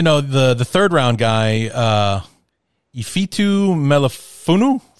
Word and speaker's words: Ifeatu Melifonwu: So Ifeatu, even though there's an E in it.